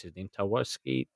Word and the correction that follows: Tarkowski: Taninski at Burnley, the centre half? – Tarkowski. Yeah.